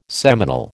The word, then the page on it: seminal: American English